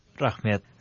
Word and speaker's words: Rahmet: How do you say thank you in Kazakh? – Rahmet. – Rahmet.